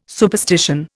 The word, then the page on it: superstition: Indian